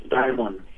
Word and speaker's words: Dimon: It's Dimon. – Dimon. Okay, cool. – Yeah. – It's like that shiny thing.